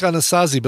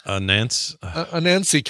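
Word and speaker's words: Anansie: I always pronounced it Skunk Anasazi, but looking how it's spelled, it's not Skunk Anasazi. – Is that like Skunk Anansi? – Anansi, kind of like Anansi Boys by Neil Gaiman.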